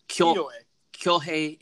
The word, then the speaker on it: Kyohei: And then supposedly this guy, I'm going to butcher his name right here, Kayohe Yamani? No, go ahead, take it. – Hold on, I'm looking, oh, Kyohei Yamane. – Yamane. – Kyohei.